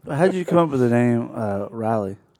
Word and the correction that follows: Raleigh: Uh, Raleigh. Raleigh. Sorry. Yeah.